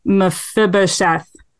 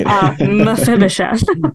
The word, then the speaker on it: Mephibosheth: This kind of gives very, very, okay, this is, I'm going to say it wrong. Mephibosheth. I think I said it right. – Sounded great. – Thank you. Yeah. I've been practicing Mephibosheth.